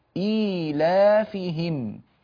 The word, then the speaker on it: إيلافهم: اعرضوا لي السورة على الشاشة أخي المخرج، فلاحظوا بارك الله فيكم، إيلافهم هذه الياء الصغيرة مثل الياء الكبيرة تماما في النطق، النطق واحد، إيلافهم.